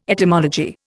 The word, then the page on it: etymology: Indian